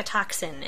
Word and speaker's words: tocsin: the call rings like a toxin, and I had to look up what a toxin is. – It's spelled T-O-C-S-I-N.